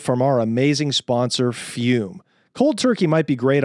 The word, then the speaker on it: Fum: We're talking about Fume, pronounced Fume, F-U-M-E, but spelled F-U-M.